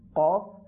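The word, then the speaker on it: কাফ (كاف): অর্থাৎ কফ বলার সময় মুখটা একটু গোল হয়ে যাবে, আর কাফ বলার সময় মুখটা একটু খুলে যাবে।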